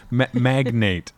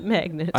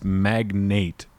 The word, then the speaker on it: magnate: She pronounces it magnet. – I know she does. And Rick pronounces it that way later when he's repeating it. And both times they say magnet. And I'm like, no, it's magnate.